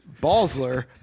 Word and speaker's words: Basler: Because in St. Genevieve, it's Balsler, but it's spelled B-A-S-L-E-R. But in St. Louis, it's Basler, which is really the way I think it should be pronounced, B-A-S-L-E-R.